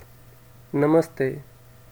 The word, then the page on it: Namaste: indian